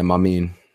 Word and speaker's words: Mamin: The fact that like I was even kind of able to say his name is Mamin. – Right. It's not Mamin. I think it's a better chance that it's Mamin. – Yeah, Mamin. There you go.